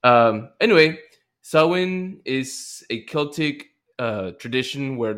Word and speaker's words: Samhain: So modern day observance can be traced to the Celtic festival of, I would say, Samhain, but it's apparently pronounced Samhain or Samhain, a.k.a.